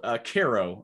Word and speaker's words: Cairo: It's called Cairo. But Cairo. It's spelled like Cairo, Egypt.